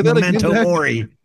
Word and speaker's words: Memento Mori: I'm going to mispronounce it, but it's like Memoriarity or something like that. – No, that's not right. It's Memento Mori. – Memento Mori, that's it, yeah. I don't know. I don't know how you say that word. – Memento. Exactly the way it's spelled.